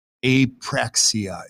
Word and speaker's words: apraxia: And then you said, you know, I'm really thinking about tongue dysfunction, and you used a term called apraxia. Did I say that right? – Yep.